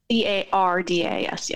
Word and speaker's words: Arcadis: And it's Arcadis, right? – Arcadis, yes.